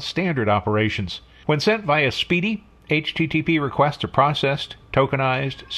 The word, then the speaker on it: SPDY: So you've probably already worked this out for yourself, but SPDY is pronounced speedy.